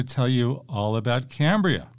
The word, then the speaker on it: Cambria: Cambria, which some people pronounce as Cambria, but I think we're going to go with Cambria.